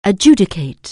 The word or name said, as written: adjudicate